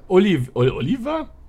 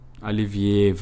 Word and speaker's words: Olivier: Olivier heißt der. – Olivier. Der Oliver? – Olivier wird's ausgesprochen.